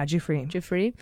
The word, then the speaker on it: Giuffre: Cooper is going to be representing Virginia Roberts Giuffre. Is that how he decided we pronounce it? – Yeah, Giuffre.